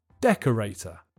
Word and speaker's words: decorator: The answer is decorator. The pronunciation this time is simple. The main stress is on the first syllable, and there's a secondary stress on the third syllable.